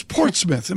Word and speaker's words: Portsmouth: It's Portsmouth.